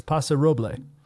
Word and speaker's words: Paso Robles: Don't pronounce the S. It's Paso Robles.